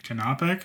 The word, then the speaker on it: canopic: The canopic bean, is that how you pronounce that? Canopic, canopic, canopic. That sounds about right.